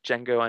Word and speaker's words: Django: It's like Django Unchained. D silent.